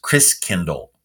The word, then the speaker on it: Christkindl: And the German pronunciation of Christ child is Christkindl.